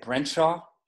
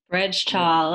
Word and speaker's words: Brejcha: Definitely Boris Brenshaw. – Mm-hmm. – What's the name again? – Boris, how do you say it? Brenshaw? – Brenshaw, like Brenshaw. Spelled B. Yeah, B-R-E-J-C-H-A.